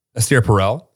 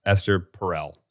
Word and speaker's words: Esther Perel: Esther Perel. That's how we say it in America. God knows how you say it in France. Not Esther Perel.